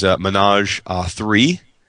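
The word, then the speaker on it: Ménage à 3: and that is Menage 3. I believe I'm saying that right, right, David? – Yes, yep.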